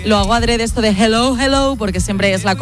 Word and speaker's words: hello: Y la segunda, dile a Elena que su dicción en castellano es la más perfecta que conozco, pero que cuando diga hello, que no pronuncie la J, que no es hello, que es hello, que la aspire la J y no pronuncie la J potente castellana.